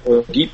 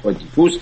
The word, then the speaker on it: ادیپوس: بله، نمایش خب بعضیا تلفظ میکنن ادیپ و بعضیا میگن ادیپوس، اما این نمایشنامه سنکا رو با عنوان ادیپوس ترجمه کردن، با همین عنوان هم به صحنه خواهد رفت.